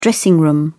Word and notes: dressing room: British English

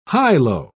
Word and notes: high-low: British English

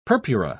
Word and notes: purpura: British English

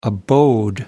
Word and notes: abode: American English

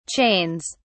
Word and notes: chains: British English

